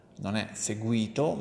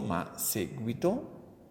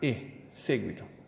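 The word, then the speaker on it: séguito: Attenzione all'accento, non è seguito ma seguito. L'accento cade sulla lettera E, seguito, che fa pensare questo termine a delle persone che seguono quel personaggio politico o qualunque tipo di personaggio.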